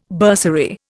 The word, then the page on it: bursary: Indian